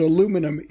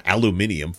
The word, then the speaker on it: aluminum: Because, but aluminum is the correct pronunciation. – Yeah, it's not aluminium.